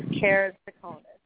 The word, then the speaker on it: keratoconus: How do you pronounce it again? – It's keratoconus.